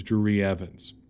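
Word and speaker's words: Jahri Evans: Lang versus Jerry Evans. I recently learned that's actually how he prefers to have his name pronounced.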